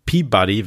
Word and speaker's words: PBDY: Und was ich noch dazu gepackt habe, worüber ich ganz gerne sprechen möchte, ist äh, The State of Mind, Mind, ein Track von Peabody wird er ausgesprochen, aber PBDY geschrieben, den er zusammen mit Annika von Exploded View gemacht hat.